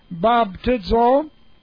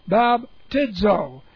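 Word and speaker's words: Baptizo (βαπτίζω): Baptizo. That's B-A-P-T-I-Z long O. Baptizo.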